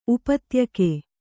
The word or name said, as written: ಉಪತ್ಯಕೆ